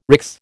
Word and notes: RICS: American English

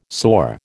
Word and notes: soar: American English

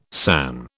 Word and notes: san: British English

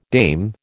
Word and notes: dame: American English

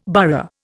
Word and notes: burgh: Indian

